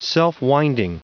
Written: self-winding

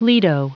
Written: lido

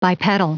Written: bipedal

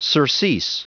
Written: surcease

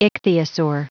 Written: ichthyosaur

